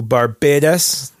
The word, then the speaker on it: Barbados: I mean, we went to Barbados. My wife is from Barbados. – How do they say it? How do they pronounce it? – Barbados.